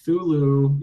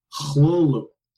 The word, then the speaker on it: Cthulhu: And I've always said that as a Cthulhu, you know, just like a K sound and then a TH sound, and that's it. But I read a little bit, and he actually had a pronunciation that he said was the correct pronunciation, which is Hlulu, Hlulu.